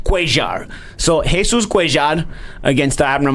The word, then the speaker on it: Cuellar: Listen, Jesus told us in the fighter meeting he likes to pronounce Cuellar. All right, believe me, I know the double L is Ya. I know that. But Jesus wants to pronounce Ya.